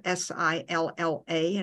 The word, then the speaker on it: silla: In a lot of dialects in Spanish, a double L equals a Y, as in the word for chair, spelled S I L L A and pronounced silla.